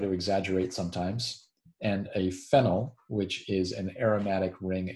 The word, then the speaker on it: phenyl: And the name phenol, which is also important to note, because sometimes in my pronunciation, I want to hit phenol, which is the aromatic alcohol, and a phenyl, P-H-E-N-Y-L, which is a substituent, an aromatic ring system as a substituent is a phenyl group.